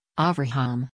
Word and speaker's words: Avraham: He praised Kushner's role in the Abraham Accords, saying he preferred the Hebrew pronunciation, Avraham.